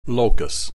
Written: locus

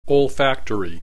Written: olfactory

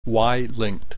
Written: Y linked

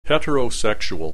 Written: heterosexual

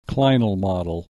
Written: clinal model

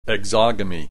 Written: exogamy

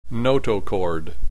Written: notochord

